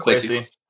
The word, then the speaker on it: Kwesi: Am I saying it right? – Kwesi? Yeah, it's Kwesi.